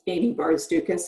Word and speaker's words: Amy Barzdukas: And I am at Amy Barzdukas as well.